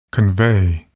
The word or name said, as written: convey